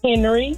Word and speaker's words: Henry: B-R-I-D-G-E-T. – What's your last name, Bridget? – Henry, H-E-N-R-Y.